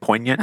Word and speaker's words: poignant: Is it poignant? Is that how you say it? Poignant? – I think so. – All right. Poignant. There you go.